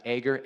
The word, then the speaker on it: Agur: Just so happens that this proverb was written by a guy named Agur. I've heard it pronounced Agur, A-G-U-R.